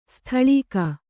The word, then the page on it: ಸ್ಥಳೀಕ: Kannada